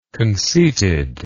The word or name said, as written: conceitedness